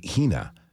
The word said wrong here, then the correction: Jina: that I pronounce it correctly. – Jina. Jina. Yeah.